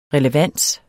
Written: relevans

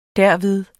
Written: derved

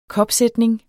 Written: kopsætning

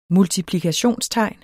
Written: multiplikationstegn

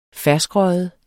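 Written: ferskrøget

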